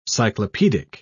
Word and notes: cyclopedic: English; British English

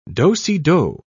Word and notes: do-si-do: British English

